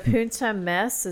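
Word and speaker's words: Punt e Mes: And then the Punta Mace, is that how you say it? – Yeah.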